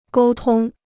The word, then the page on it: 沟通: gōu tōng